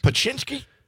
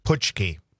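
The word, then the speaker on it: Pączki: Well, it's Paczynski? Paczynski? – No. Puchki.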